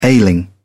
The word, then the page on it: ailing: British English